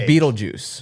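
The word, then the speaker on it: Betelgeuse: I've watched that movie so many times, but I didn't realize the real Beetlejuice is Beetlegeese, like B-E-T-E-L. – Yes. Oh. – Huh? – It's like B-E-T-E-L-G-U-I-S-E. – Yeah, but it's pronounced like juice. – But it's pronounced Beetlejuice.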